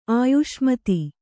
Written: ಆಯುಷ್ಮತಿ

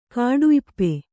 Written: ಕಾಡುಇಪ್ಪೆ

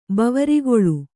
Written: ಬವರಿಗೊಳು

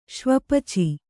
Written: ಶ್ವಪಚ

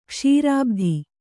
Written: ಕೇಸರಿಭಾತು

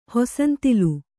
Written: ಹೊಸಂತಿಲು

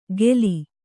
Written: ಗೇಲಿ

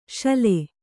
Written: ಸೂಲೆ

